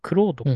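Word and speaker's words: Claude: まあそのチャット GPT のまあライバル、競合的な、クロードか。読み方としてはクロードみたいロード。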